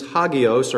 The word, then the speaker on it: hagios: It comes from a root word that means hagios or hagios. That's the pronunciation of it.